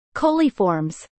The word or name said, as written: Coliiformes